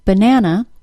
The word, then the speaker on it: banana: For example, the word banana is a three-syllable word and the stress is on the middle syllable. Both the first and third syllable of banana are reduced to schwa.